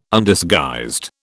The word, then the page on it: Undisguised: British English